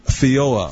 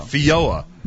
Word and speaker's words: Feijoa: This one you told me you pronounce how? Feijoa. Feijoa.